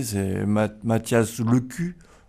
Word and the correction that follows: Lescu: Lescu. Mathias Lescu. – En tout bien tout honneur, Lescu.